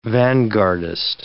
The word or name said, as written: vanguardist